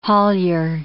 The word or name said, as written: haulier